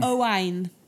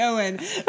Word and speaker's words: Owain: Is that Owen or Owain? – Owain, Welsh. – Okay. – Would some people pronounce that Owen? – Not if you're Welsh, no. – Okay. – If you're going to anglicise and Americanise it would be Owen. But it's Owain.